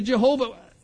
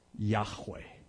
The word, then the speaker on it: Yahweh: And so when translators tried to figure out how to pronounce it, they took the vowels from the word Lord, Adonai, and added them to Jehovah. That's not the way it's pronounced. Best guess is it was something like Yahweh.